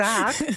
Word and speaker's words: Haag: Dus Romy Haag. Ja, dus ik ga ook zeggen in Nederland. Dus de Haag. Maar omdat zij vooral groot is geworden in Duitsland, hoor ik het meestal op zijn Duits uitgesproken.